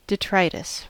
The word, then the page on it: detritus: American English